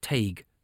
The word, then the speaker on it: Taig: Taig is pronounced Tay with a hard G on the end. Try it. Taig.